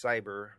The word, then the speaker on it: Psyber: First off, I'm going to give you a definition of what PSYBER, pronounced cyber, really means.